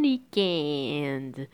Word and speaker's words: weekend: O inglês fala weekend, né? Bem curto e rápido. O francês é bon weekend. Prolonga essa pronúncia.